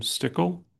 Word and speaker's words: Stickle: My name is Herbert Edwin Stickle.